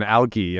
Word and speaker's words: algae: That's the pronunciation, algae. Am I already mispronouncing it? – Either is fine. It is a dialect difference. So if you're operating out of the U.S., people say algae. If you're operating out of the U.K., my company is based in Australia.